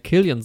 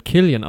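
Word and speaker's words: Cillian: halt, dieses C wird immer mit Z ausgesprochen, aber ich habe dann irgendwann mal ein Christopher Nolan-Interview gesehen, wo er halt Killian sagt und anscheinend ist das halt, wird es halt Killian ausgesprochen.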